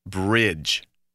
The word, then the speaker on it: bridge: Well, the letters D, G, E can also stand for the soft G sound. Listen to this word, bridge. Bridge is spelled B-R-I-D-G-E. The letters D-G-E stand for the soft G sound, J.